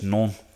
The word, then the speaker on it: Nantes: I don't know how to pronounce that. It's written as Nantes. – Yeah, I was going to say Nantes. – I think it's just non.